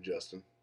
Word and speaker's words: Justin: Yeah, I did say Ustin then again because technically in Spanish, the J sounds like H, but then I actually asked a Mexican person and they said no, they would just say my name, Justin. So it's supposed to be Me llamo Justin.